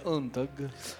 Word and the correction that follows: Unstrung: Unstrung. Pinocchio Unstrung.